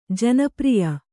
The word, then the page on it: ಜನಪ್ರಿಯ: janapriya